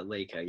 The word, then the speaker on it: Laika: That was Cor- Coraline was made by uh, Laika.